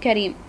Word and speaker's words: Karim: Yes, it's Yusuf Karim. – Could you spell that for me? – Yes, it is Yusuf with Y-U-S-U-F and Karim is K-A-R-I-M.